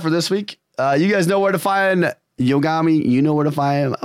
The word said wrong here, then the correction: Yogomi: I got to say it right. I'm sorry. Yogomi. Sorry. You know where to find Yogomi. I'm going to get it.